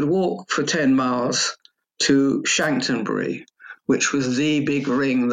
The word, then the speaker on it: Chanctonbury: And forgive me if I mispronounce it. Chanctonbury Ring, is that correct? – That's right, yeah.